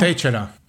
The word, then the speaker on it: Teixeira: Teixeira.